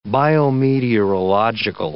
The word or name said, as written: biometeorological